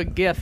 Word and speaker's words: GIF: Turn myself into a GIF. And yeah, I say GIF with a G.